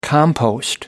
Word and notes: compost: American English

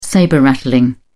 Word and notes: sabre rattling: British English

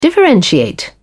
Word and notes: differentiate: British English

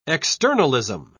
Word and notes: externalism: British English

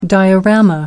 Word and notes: diorama: American English

